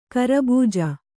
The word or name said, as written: ಖರಬುಜ